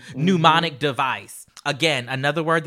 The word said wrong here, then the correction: mnemonic: Uh, uh, mnemonic. You said mnemonic as if it was like pneumonia.